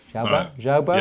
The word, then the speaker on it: Jaubert: with the news that France's Anouk Jaubert, I think I pronounced that right, and Italy's Mika Pekularaz. Jaubert. J-A-U. – Okay. – Jaubert? – Yeah, I bow to your French knowledge.